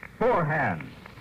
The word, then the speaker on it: Forhans: It's spelled F-O-R-H-A-N-S. It's pronounced Forehand.